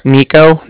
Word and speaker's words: Niko: So, like I said, my name's Nico. That's spelled N-I-K-O. – Mm-hmm. – And then the Bank of Cardiff is spelled C-A-R.